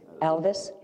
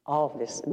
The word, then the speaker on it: Alves: So, I'm going to read something from, it's from the Chronicles of Reuben, R-U-B-E-M, and his last name is A-L-V-E-S. He's Brazilian. How do you pronounce A-L-V-E-S? – In Brazil? – In Elvis? Alvis, not Elvis. Alvis, is that correct? – Yes.